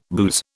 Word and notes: booze: British English

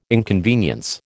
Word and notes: inconvenience: American English

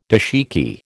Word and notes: dashiki: American English

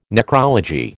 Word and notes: necrology: American English

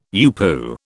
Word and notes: UPU: British English

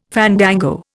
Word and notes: fandango: Indian

